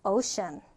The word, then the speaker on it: ocean: ocean. ocean. 자, 지금 보면 이렇게 슈 발음이에요. 그래서 ocean. ocean. 이렇게 발음이 되죠.